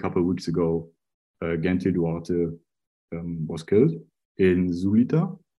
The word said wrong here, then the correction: Zulia: I think. Zulia is how you pronounce it, I guess.